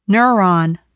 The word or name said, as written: neuron